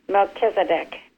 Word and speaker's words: Melchizedek: And did I say that right? – It's pronounced Melchizedek.